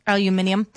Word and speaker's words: aluminium: Aluminium. – Are you one of those people that can't pronounce that? – No, it's my mom used to extrude aluminium, and that's how they would pronounce it in India.